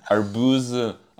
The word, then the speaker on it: Arbuz: Arbuz, Arbuzin, it's hard to pronounce.